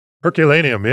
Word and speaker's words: Herculaneum: You went to the Herculaneum, I think it's pronounced. – Herculaneum, yeah.